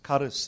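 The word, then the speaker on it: charis (χάρις): The Greek pronunciation is charis. CH in any Greek word, you must know this, is always pronounced K.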